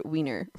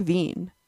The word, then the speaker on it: Wien: Okay, due to the origin of the word wiener, Vienna is pronounced like Wien in German.